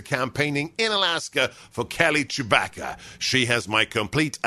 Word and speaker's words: Tshibaka: Her name, she told me, it's spelled T-S-H-I-B-A-K-A, but she said, it's okay if you're a Star Wars fan. If I'm a Star Wars fan, it's pronounced Chewbacca.